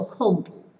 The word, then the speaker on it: 昆布: 那昆布的话，在日文当中，我们就会把它念作控布。